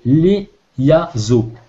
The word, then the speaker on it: Leyaso: Le code, c'est LEYAZO. Alors ça s'écrit L-E-Y-A-S-O.